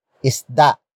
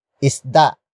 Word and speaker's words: isda: And that is isda. Isda.